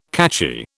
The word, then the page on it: catchy: British English